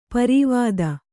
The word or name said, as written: ಪಾರಾವತ